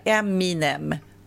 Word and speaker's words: Eminem: Men vad är han, rapparen, heter inte han M&M? – Ja, nej, han heter ju Eminem. – Jaha. – Inte M&M. Men du uttalas likadant.